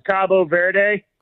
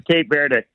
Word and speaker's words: Cabo Verde: They in Spanish, it's Cabo Verde. – Cabo Verde. – Cabo San Lucas or whatever in Mexico. Cabo Verde. But okay. – The English pronunciation is Cape Verde.